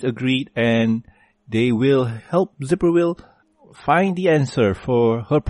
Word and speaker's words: Zipporwhill: With another satisfied client, they proceed on to the next one, which would be Zipperwill? Wow, is that how you pronounce her name? – Yep, Zipperwill.